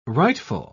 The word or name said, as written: rightful